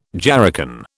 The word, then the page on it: jerrycan: British English